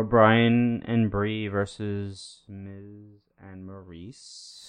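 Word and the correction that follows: Miz & Mrs.: It's Miz and Mrs. Say it right.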